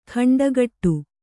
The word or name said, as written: ಖಂಡಗಟ್ಟು